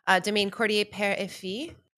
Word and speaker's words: Domaine Cordier: So this is Domaine Cordier Père et Fille. – Beautiful pronunciation.